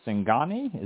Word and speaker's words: Singani: Singani, is that how it's pronounced? – Yeah.